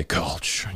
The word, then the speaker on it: Kölsch: In German, you would say Kölsch. Yes. And here you say Kölsch when you try to pronounce it correctly, if you would be me.